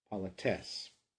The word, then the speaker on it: politesse: Take note that the accent falls on the final syllable of this word, politesse.